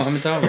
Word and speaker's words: amygdala: and I'm going to massively mispronounce both these words because I'm heavily dyslexic. The frontal cortex, okay, got them right, and the amygdala. – Amygdala, that's the one.